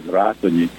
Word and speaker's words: Ratonyi: And when I said Ratonyi, he looked up and asked, "How do you spell it?"